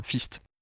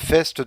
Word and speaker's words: FEAST: Je crois que ça se prononce FEST, du coup. – Le FEST, oui, je sais plus, F-E-A-S-T. – Ouais, je crois que ça se prononce, sinon ça fait point, FIST.